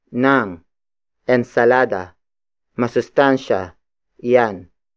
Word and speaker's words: ensalada: It is the direct object marker, indicating that the next word is what you should eat, and that is ensalada. Ensalada. Ensalada. Ensalada is spelled as E-N-S-A-L-A-D-A.